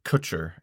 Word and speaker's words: Kutcher: Kutcher. Ashton Kutcher. – Okay, Kutcher and Kunis. – K-U-T-C-H-E-R.